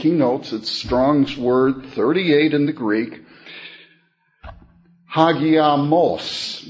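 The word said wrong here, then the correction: Hagiasmos (ἁγιασμός): is the pronunciation that the concordance will give. Hagiasmos, rather.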